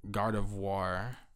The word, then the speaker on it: Gardevoir: Gardevoir.